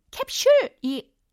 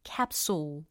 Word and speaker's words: capsule: 캡슐이 아니고 capsule. 이렇게 발음을 해주시면 좀 더 세련돼지죠.